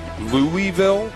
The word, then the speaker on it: Louisville: Louisville? Louisville. – Louisville. – That's what I would, you know, like the locals say it.